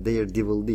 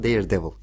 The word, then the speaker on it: Daredevil: Daredevil değil ama Daredevil şeklinde okunur.